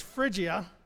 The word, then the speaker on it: Phrygia: how do you pronounce P-H-R-Y, it's fridge, like a refrigerator, that wasn't in the pronunciation, but I put that in, it's Phrygia. It was F-R-I.